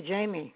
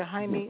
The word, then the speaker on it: Jaime: And actually, that's how his name is pronounced. I was calling you Dr. Jamie. I didn't know that the J was silent. So forgive me. You are Dr. Jaime Romo.